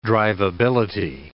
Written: driveability

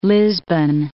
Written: Lisbon

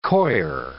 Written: coir

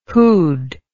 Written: whod

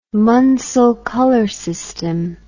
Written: Munsell color system

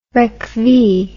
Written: recvee

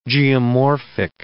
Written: geomorphic

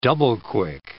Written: double-quick

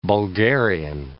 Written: Bulgarian

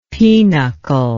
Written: penuchle

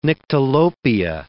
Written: nyctalopia

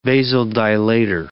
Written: vasodilator

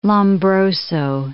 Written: Lombroso

